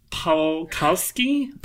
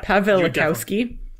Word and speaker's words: Pawlikowski: Pawlikowski? – Pronounce it as if the W's are V's.